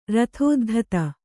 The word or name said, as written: ರಥೋದ್ಧತ